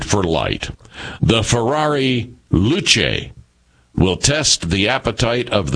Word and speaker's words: Luce: The Luce, and it has here pronounced Luce, Will, because I was trying to figure out how to pronounce this, as a matter of fact, and here in the story it says the Luce, pronounced Luce.